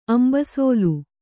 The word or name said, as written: ಅಂಬಸೋಲು